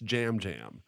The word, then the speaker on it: Yam Yam: It's phonetically looks like Yam Yam, but I think it's pronounced Jam Jam.